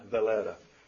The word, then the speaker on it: Reina Valera: Reina Valera.